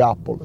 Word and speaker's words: Apple: a parte che Apple si pronuncia Apple,